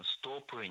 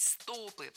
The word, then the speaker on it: стопы: Ну, прежде всего, вы правильно заметили, это упражнение на ноги, на стопы, на икроножные мышцы, но зажимать колени, ноги, стопы не рекомендуется. – А еще не рекомендуется говорить «стопы», потому что правильно – «стопы». Это слово всегда имеет ударение на окончании во всех формах.